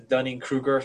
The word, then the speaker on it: Dunning-Kruger: we talk about like the Dunning-Kruger effect.